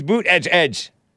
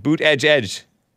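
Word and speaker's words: Buttigieg: It's Boot Edge Edge. – Boot edge edge. – Boot edge edge. – Wasn't that like the goofy way that Trump used to pronounce it? – It is, but that's how I was told is how you pronounce it. Boot edge edge. You're supposed to say it fast, different. – Like boot edge edge. But it's when they spell out the pronunciation, they say boot edge edge.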